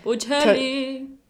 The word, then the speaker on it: Bocelli: Our drink of the day is the Bocelli Toscana. I'm probably saying this wrong. – Bocelli.